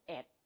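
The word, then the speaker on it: @: 这个符号，今天的题目，人生 at 光，这样读过，不同的地方有不同的读法，但是香港普遍读 at，大家都见过这个符号，是不是？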